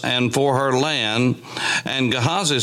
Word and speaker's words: Gehazi: We call him Gehazi. Now remember, there is no long I as such in the Hebrew language, and Gehazi is the way that that would be pronounced or something akin to that.